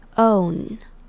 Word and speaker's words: own: 那这边的 own 的 ow 发成 o 的音，像是否定的说不要的那个 no 的 o 也是一样的发音，所以是 own。